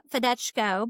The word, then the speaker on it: Fedeco: Fedeco, but it's okay. – I don't know if you've listened to my other episode, but I've needed correcting. So Fedeco. So everything is sounded out like it's spelled.